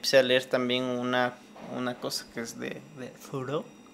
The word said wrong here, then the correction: Thoreau: Henry David Thoreau.